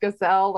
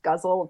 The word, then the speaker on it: ghazal: So for those of you who aren't familiar with a ghazal, it looks like it should be pronounced gazelle or gazelle, but it's pronounced guzzle.